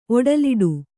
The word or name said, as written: ಒಡಲಿಡು